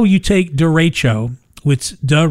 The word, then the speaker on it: derecho: How do you pronounce derecho? And that's so it's da sounds like a derecho. Derecho. – Derecho. It is derecho. Yes. We've been saying it wrong forever.